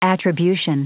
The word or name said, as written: attribution